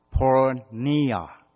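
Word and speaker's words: pornia (πορνεία): 它的发音是这样发的，pornia。如果你要用英文来写的话，是 pornia，就是后来我们把它变成是 pornography， porn，色情网页、色情的这些图画、图像、色情的这个事情。但是在原来的文字里面，pornia 是来自什么地方呢？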